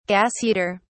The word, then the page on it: gas heater: British English